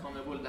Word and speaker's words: CONMEBOL: Yeah, North America, Central America, and then I don't know how to pronounce CONMEBOL. – CONMEBOL. – Yeah. – CONMEBOL, that would be South America.